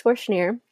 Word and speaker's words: Schnier: So my Instagram is Jess_ Schnier. That's Jess_SCHNIER. Rhymes with beer.